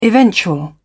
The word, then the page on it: eventual: British English